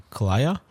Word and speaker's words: Kalaya: It's K-A-L-A-Y-A, but it's like, it's Thai, and like the way that the women say. Yeah. Dude, how would you say that? – Kalaya? – Kalaya is how she says it.